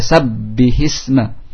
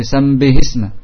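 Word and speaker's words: sabbihisma (سبح اسم): Ya, Sabbihisma Rabbikal A'la, pokoknya. Sabbihisma Rabbikal A'la. Ya, mungkin dia ucapan apa? Sambihisma. Sambihisma, ya. Tidak jelas ba'nya. Tidak sabbihisma, tapi sambihisma. Seolah-olah bercampur dengan mim.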